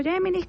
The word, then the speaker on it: Gemini: ¿Cómo descargo Gemini? Casi todos los que tienen Android tienen Gemini ya incorporado, se escribe así, Gemini, se pronuncia Gemini, pero para que ustedes lo puedan buscar en español, Gemini con G.